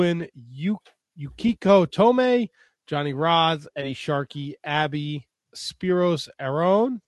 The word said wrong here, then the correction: Arion: Did I say it right? – Arion.